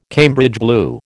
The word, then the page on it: Cambridge blue: American English